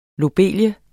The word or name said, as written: lobelie